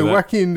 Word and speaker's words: Joaquin: No, it's like Joaquin, isn't Joaquin? – It? – Joaquin? Okay, Joaquin then Phoenix.